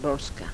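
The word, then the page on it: boska: dialetto croato di Lukezi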